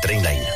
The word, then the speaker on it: Trainline: S'escriu millor preu garantit. Es pronuncia Trainline.